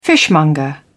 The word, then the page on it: fishmonger: British English